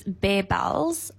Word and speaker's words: Bear Bells: So firstly, it's Bear Bells, bear like a bear, not bar like a chocolate bar.